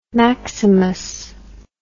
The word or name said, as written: maximus